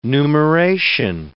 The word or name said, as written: numeration